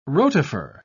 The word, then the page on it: rotifer: British English